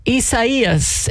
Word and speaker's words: Isaias: Isaias is the pronunciation. It's the Spanish pronunciation of basically Isaiah.